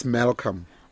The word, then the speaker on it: Malcolm: The L, the last L is silent. – Yes, into engicabangile. So ngicela ungishole lena. – It's Malcolm.